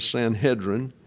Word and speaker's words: Sanhedrin: The pronunciation of this is Sanhedrin, And then synedrion is the pronunciation in the Greek.